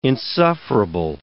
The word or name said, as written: insufferable